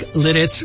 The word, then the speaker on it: Lititz: L-I-T-I-T-Z.